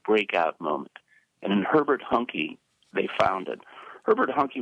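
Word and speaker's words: Huncke: Talk about the role in their social and literary equation played by Herbert Hunke, which rhymes with junkie, which was only one of his antisocial sidelines.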